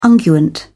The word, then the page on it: unguent: British English